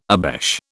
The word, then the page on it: obeche: British English